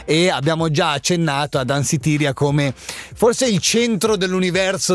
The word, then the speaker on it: Danceteria: Wow, si chiama Danceteria, ok? È così la pronuncia? Danceteria, eccolo lì.